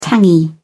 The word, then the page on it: tangy: British English